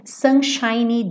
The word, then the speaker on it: sunshiny: Não esquece desse Y, tá? Sunshiny, porque ele tá fazendo adjetivo. Lembra que eu falei para você? Sunshine termina com E e o E é mudo, seria o brilho do sol, tá? E sunshiny tá fazendo adjetivo. Então, esse Y tem que aparecer.